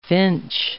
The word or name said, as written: finch